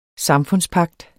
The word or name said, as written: samfundspagt